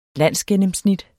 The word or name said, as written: landsgennemsnit